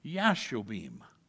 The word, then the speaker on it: Jashobeam: Yours has a J, but there is no J in the Hebrew alphabet, so his name would be pronounced Jashobeam.